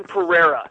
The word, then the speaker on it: Pereira: I don't know how do you say his last name? Pereira? – Say his last name, Kevin Pereira. Yeah.